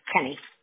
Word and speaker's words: Kenney: Is your last name Keeney as well? K-E-N-N-E-Y? Did I pronounce yes. – that right? Okay. – Kenny. Yeah. – Kenny. Okay.